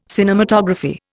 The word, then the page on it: cinematography: Indian